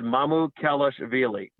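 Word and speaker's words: Mamukelashvili: I asked him, and he said Mamu Kalashvili. He wants it pronounced that way.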